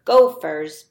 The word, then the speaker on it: gopher: So gopher has that f sound in the middle, but if you were to see the word gopher in a book or written down, it's not spelled with an F, even though it sounds like it. It's spelled with our new digraph sound that we're learning. It's P-H says F.